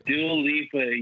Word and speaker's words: Dua Lipa: Dua Lipa? Am I saying that right? – Dua Lipa, yes, yes. You're saying it, you're already saying it better than Wendy Williams.